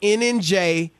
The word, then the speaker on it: ENEJE: It's spelled E-N-E-J-E. It's pronounced NNJ.